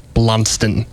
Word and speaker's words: Blundstone: It is Blundstone. You are saying it absolutely wrong. – You are kidding me. You are both saying it wrong.